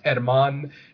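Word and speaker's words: Germán: Our EVP expert's name is Herman Domingo, spelled G-E-R-M-A-N. So it looks like German, but it's Spanish, so it's pronounced Herman.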